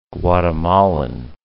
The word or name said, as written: Guatemalan